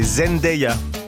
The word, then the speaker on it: Zendaya: Par contre, j'ai appris qu'il fallait prononcer Zendaya.